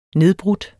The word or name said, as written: nedbrudt